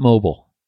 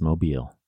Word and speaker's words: Mobile: But it is not Mobile, Alabama. It's Mobile, Alabama.